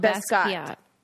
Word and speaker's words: Basquiat: And they have that look of like almost like, what is the artist who looks like John Batiste, but Basquiat. Basquiat. – Yeah. – Basquiat. – And Basquiat. I always pronounced the name wrong.